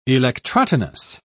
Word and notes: electrotonus: British English

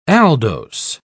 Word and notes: aldose: British English

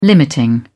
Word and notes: limiting: British English

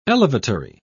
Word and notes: elevatory: British English

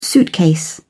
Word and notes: suitcase: British English

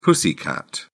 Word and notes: pussycat: British English

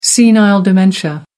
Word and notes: senile dementia: American English

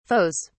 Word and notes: foes: British English

